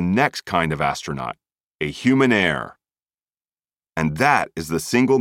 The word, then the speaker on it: Humannaire: In fact, note, human heir, spelled HUMANNAIRE, rhymes with human rare.